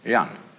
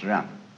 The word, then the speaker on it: Jen: In Confucius philosophy, the fundamental virtue of a human being is called Yan, spelled J-E-N for reasons best known to Chinese scholars. I don't know what they are, but it's pronounced Yan.